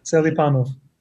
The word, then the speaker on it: Selipanov: How did I say that? – Is that right? – Good enough. – Go ahead and say it. How do you say it? – Selipanov. – Selipanov. – Yeah, that's not too far off. – A little wrong. Just a moving accent here and there.